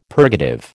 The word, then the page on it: purgative: American English